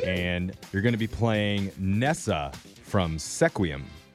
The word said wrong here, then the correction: Sequim: Squim. – Why is there S-E? – That's how you say it. – Squim? – Yeah.